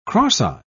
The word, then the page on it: cross-eye: British English